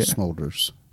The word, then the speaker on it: Smulders: Smulders, like shoulders, but with an M.